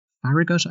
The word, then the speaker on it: Farragut: Farragut, I believe. F-A-R-R-A-G-U-T. – Farragut, yeah.